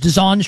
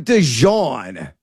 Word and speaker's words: DeJuan: And are you is it DeJuan? Because I've like pronounced his first name like 15 different times. DeJuan Stribling is what we're going with. Is that right? – I go with DeJuan. – DeJuan. Stribling. That's probably right.